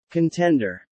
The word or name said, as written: contender